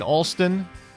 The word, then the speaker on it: Alston: It's going to be live music by, I hope I'm pronouncing this right, Alston Toller. It's A-L-S-T-O-N. So I'm going to go with Alston.